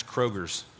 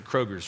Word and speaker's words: Kroger: It's kind of like how we pronounce Kroger's. And it's actually Kroger, but we pronounce it Kroger's for some reason.